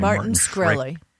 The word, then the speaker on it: Martin Shkreli: Martin Shkreli.